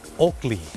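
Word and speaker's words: Oakley: โอกลี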